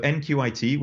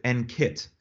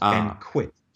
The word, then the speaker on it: NQIT: So NQIT, which you can say, you can pronounce, but we try to get people to call it NKIT and not NQIT, just because the word quit isn't.